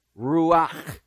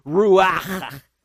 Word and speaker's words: ruach: Take, for example, the Hebrew word for spirit, which is ruach. You can't say that properly without a strong sound of breath. It is pronounced ruach. Okay, not really that.